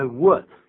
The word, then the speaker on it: would: 这个 I'd be glad to, 这个 I'd 是 I would, 就是 W 怎么拼的呢？ – W-O-U-L-D, W-O-U-L-D, would, L 不发音的。